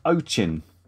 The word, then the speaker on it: Ochin: The Ochin.